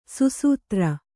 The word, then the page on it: ಸುಸೂತ್ರ: susūtra